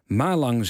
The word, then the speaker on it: Malang: Er brak paniek uit toen de politie traangas inzette vanwege rellen in het stadion van Malang. Malang zeg ik dus, maar luisteraar Jeroen zegt nee, die klemtoon hoort op de tweede lettergreep, dus Malang.